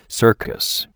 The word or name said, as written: circus